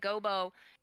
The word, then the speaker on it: Gobo: But Wembley does struggle to find a job, and we come across, is it Gobo? Is that how you pronounce his name? – Yeah, Gobo.